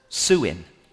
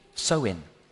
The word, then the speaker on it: Samhain: Or I think actually you pronounce it Suin. Soin, but it looks like it's spelled Samhain.